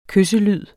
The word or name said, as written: kysselyd